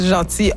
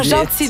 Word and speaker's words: Jeanty: Alors, moi, j'ai rencontré Rachel Gentil en 2008. – Ah, Gentil, donc on dit. Ça s'écrit J-E-A-N-T-Y.